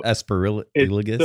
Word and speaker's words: Aspergillus: So Aspergillus.